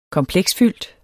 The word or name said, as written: kompiler